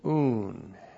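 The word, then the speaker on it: Un (οὖν): Wherefore, before the churches, this is the conjunction un plus the nominative singular of prosopon plus the genitive plural of ecclesia with a definite article. The conjunction un. O-U-N. Un.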